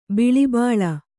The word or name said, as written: ಬಳಬಳ